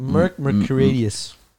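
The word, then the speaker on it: Merck Mercuriadis: Founder of Hypnosis, Marek, I'm not even going to try Merc, to pronounce Merc, what does it say? Merc Mercurius. – You got it.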